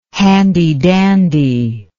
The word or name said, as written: handydandy